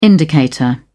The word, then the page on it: indicator: British English